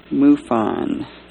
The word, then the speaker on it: MUFON: who is the Eastern Regional Head of MUFON. Did I say that right? – Right.